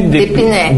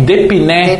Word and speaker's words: Depiné: É, teu sobrenome, Fabiana, como que eu pronuncio? É Depiné? – Depiné. Depiné. Depiné.